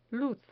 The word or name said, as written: luz